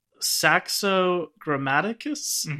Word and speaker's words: Saxo Grammaticus: This is a 10th century Scandinavian legend, which was transcribed by the Danish historian Saxo Grammaticus in his Gesta Danorum series. How was that? – I feel like that you definitely got the name right.